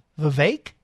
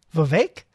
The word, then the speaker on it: Vivek: Let's see, he said pronounce it. It's pronounced like cake. So Vivek? That's it? Vivek?